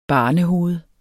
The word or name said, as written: barnehoved